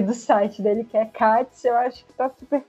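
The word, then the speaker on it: Kac: É Katz mesmo que fala, né? – É Katz.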